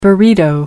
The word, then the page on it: burrito: American English